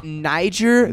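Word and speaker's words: Nyjer: Nihar Morgan? Something? – It's actually pronounced Niger Morgan. Niger Morgan.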